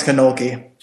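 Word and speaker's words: Kalnoky: Meanwhile, the lead vocalist of Streetlight, a guy called Thomas Kolsky, I think that's how you pronounce his name, right? Kolsky? – Thomas Konolke. – Konolke.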